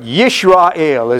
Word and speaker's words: Yisrael: But Yisrael is the way it's pronounced in the Hebrew.